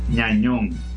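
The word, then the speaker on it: Gnagnon: Lloris Ñañón, se escribe G-N-A-G-N-O-N. Y como la G-N se pronuncia ñ en francés, Ñañón Así que Nañón va a tener que ponerse en forma, entonces no, entonces no va a, conseguir contrato.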